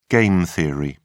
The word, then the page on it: game theory: British English